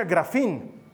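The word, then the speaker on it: grafín (γράφειν): Eh, la palabra griega que se traduce por escribir se pronuncia grafín pero la palabra que se utiliza en este pasaje es catagrafín, que literalmente significa escribiendo algo en contra de.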